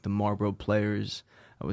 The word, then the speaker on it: Marlboro: Marlboro.